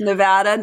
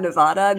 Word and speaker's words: Nevada: it's Nevada, not Nevada. They get very upset if you get it wrong,